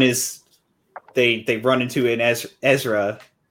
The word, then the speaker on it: Ezra: That's exactly how you say it. Ezra.